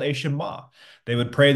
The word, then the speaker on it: Shema: This is a Hebrew word, and it's pronounced Shema, okay?